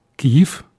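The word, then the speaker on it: Kyiv: Nous sommes donc en 1876 à Kiev, comme nous prononçons ici sur Radio Cité, étant donné que cette prononciation est plus correcte parce qu'elle reflète le nom de ce toponyme en ukrainien et pas en russe.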